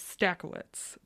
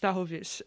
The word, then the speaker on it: Stachowicz: Everyone knows me as Stakowitz. My last name is technically pronounced Stachowicz, and that's how the rest of my family pronounces it. – That's why. – That's the very Polish way of saying it.